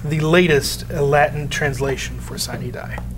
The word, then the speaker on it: sine die: I always pronounce it sine die. I think there are some different pronunciations of the Latin there. – Sine die is, I think, the appropriate Latin pronunciation.